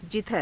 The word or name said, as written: jidhara